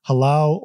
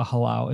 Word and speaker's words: halau: And she is also a member of the world-renowned Halau o Kekuhi, based right here in Hilo.